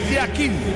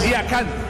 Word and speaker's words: Yakin: En el suizo se pronuncia así, Murat Yakin. En turco, Yakan. Cambia la última parte de su apellido.